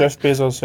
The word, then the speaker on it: Jeff Bezos: zum Beispiel Jeff Bezos, zum Beispiel reicher zu machen. Spricht man den so aus? Ich habe noch nie seinen Namen gesagt. – Jeff Bezos, ja. So kenne ich es auch.